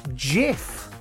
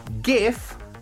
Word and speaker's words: GIF: And for his acceptance speech, Johnny, he played a short video on the big screen that actually says it's pronounced GIF with GIF. – A J. Yes. And not GIF with a G.